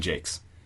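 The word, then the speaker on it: Jacques: I thought it was beautiful, and it reminded me, and I'm sure that you've heard this probably before, of this world of Brian, I don't know how to pronounce his last name, Jock or Jockus or something. – He always pronounced it Brian Jakes. – Jakes? Okay. And I read those novels when I was younger, Redwall, and it's a story about animals, kind of.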